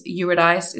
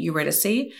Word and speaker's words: Eurydice: Eurydice is how a lot of the media pronounces her name, but I believe she pronounced it Eurydice.